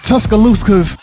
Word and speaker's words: Tuscaloosa: Tuscaloosa's. – In the building. – Did I say it right? – It's Tuscaloosa. – Tuscaloosa. Yeah. – You close enough.